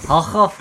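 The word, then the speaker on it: Hough: Haug.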